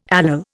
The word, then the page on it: allele: Indian